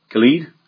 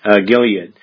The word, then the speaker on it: Gilead: In those, Gilead? I said it wrong? Gilead, thank you. Gilead.